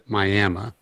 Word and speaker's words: Miami: We say in Oklahoma, Miami. – Oh, shit. – there is a Miami, Oklahoma, but all the locals pronounce it Miami.